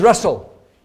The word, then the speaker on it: Russell: Now, my name is Russell. Two S's, two L's. Don't misspell it. My name is Russell.